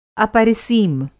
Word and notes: अपरिसीम: Hindi

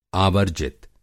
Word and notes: आवर्जित: Hindi

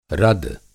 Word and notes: रद: Hindi

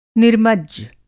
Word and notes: निर्मज्ज: Hindi

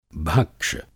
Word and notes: भक्ष: Hindi

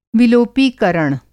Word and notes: विलोपीकरण: Hindi

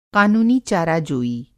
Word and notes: क़ानूनी-चाराजोई: قانونی چارہ جوئی

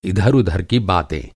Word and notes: इधर-उधर: اِدھر اُدھر

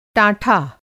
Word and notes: टाँठा: Hindi

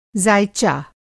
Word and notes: ज़ायचा: زائچہ

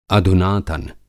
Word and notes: अधुनातन: Hindi

